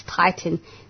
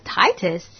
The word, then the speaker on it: Titan: It's Titan. No, you're fine. T-I-T-A-N. Just in case any of his friends are listening and they're like, Titus? What the hell is that?